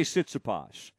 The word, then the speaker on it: Tsitsipas: But I say Tsitsipas. But it's with a T, but the T is silent.